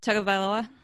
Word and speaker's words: Tagovailoa: Tagovailoa. – Tagovailoa.